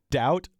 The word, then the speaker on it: doubt: doubt, get back to that silent B, doubt, D-O-U-B-T, doubt, D-O-U-T-E.